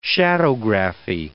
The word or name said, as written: shadowgraphy